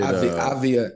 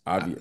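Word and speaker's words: Avdija: The kid, Avia, Denny Avia. – yes, Avia.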